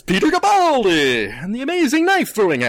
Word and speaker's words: Capaldi: Which is correct. It's not Capaldi. It's Capaldi.